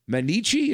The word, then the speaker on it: Mainichi: Is it the Mainichi? Is that how you say it? – Yeah. – Am I saying that right? – It's good.